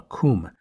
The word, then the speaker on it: cwm: Now, at the end of the glacier is a great, steep-walled, semicircular basin called a coom. This specific coom was dubbed the Western Coom. A side note about this, coom is spelled C-W-M, which is pretty odd. It is Welsh and it means valley.